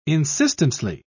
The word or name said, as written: insistently